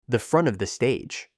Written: the front of the stage

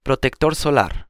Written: protector solar

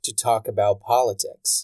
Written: to talk about politics

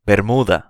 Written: bermuda

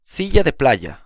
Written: silla de playa

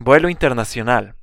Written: vuelo internacional

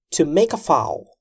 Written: to make a foul

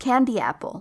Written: candy apple

